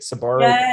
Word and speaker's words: Sbarro: Sbarro.